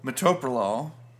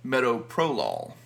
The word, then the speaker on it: metoprolol: A lot of people that don't know how to pronounce it call it metoprolol.